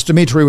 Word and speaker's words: Dimitri: And it's Dimitri with three I's, D-I-M-I-T-R-I.